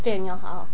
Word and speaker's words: Faneuil Hall: Faneuil Hall.